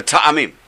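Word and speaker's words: ta'amim (טעמים): Or Sephardim we call them ta'amim. In Hebrew they call ta'amim. Trop is, I think, Yiddish. So let's stick to the Sephardic pronunciation. The ta'amim.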